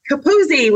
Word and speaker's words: Capuzzi: This week, we have the amazing Mike Capuzzi with us.